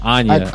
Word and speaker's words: Anya: Have you ever seen it's Miss Anaya? – No, Anya. – Anya. – A-N-Y-A. – Anya, I said it wrong. Sorry. A-N-Y-A.